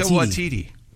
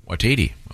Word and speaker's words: Waititi: Taika Waititi. – Waititi.